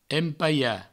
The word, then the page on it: empalhar: empaia